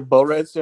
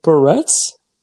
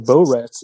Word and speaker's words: barrettes: Something to put your barrettes in? I don't know. – Why are you pronouncing it like that? Barrettes? – Yeah. – Oh, barrettes. – You were saying like Borats. It sounded weird.